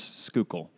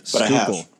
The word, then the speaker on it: Schuylkill: No, it's Schuylkill. Have you seen that word before? – I have seen that word before. I don't know where. – Yeah, it's Schuylkill. I had to say it like 47,000 times until I like was saying it correctly.